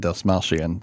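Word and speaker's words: Dastmalchian: David Dasmalchian. Did yeah. I get that right? – Yeah. – Okay, good.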